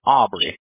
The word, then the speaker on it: Aubrey: My name is Aubrey. – Aubrey, can you spell that? – Yeah, so that's A-U-B-R-E-Y.